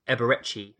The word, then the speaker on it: Eberechi: I believe it is Eberechi Eze. – Okay. Eberechi Eze.